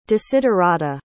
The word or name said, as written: desiderata